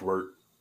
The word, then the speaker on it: wort: It's wort. Pronounced wort.